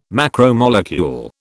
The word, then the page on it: macromolecule: British English